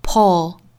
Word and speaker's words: pull: 比如说刚才讲到的这个 pull，不管英音还是美音，这个词正确的发音都是发 o pull pull。